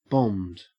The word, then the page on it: bombed: Australian English